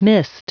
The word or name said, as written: mist